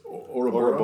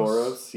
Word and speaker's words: Ouroboros: It's sort of like the Ouroboros. Is that how you pronounce that? – I think it's Ouroboros. – Ouroboros, yeah.